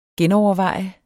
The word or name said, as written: genoverveje